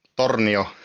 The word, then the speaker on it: Tornio: Yeah, so I'm from a place called Tornio in Lapland province.